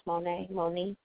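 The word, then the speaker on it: Mo'ne: I remember it's pronounced Moni Davis gets the cover of Sports Illustrated.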